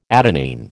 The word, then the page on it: adenine: American English